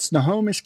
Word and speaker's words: Snohomish: Well, we've got Sean Maxwell here with us from, I'm going to make sure I say this right, Snohomish County. Is that correct? – You got it.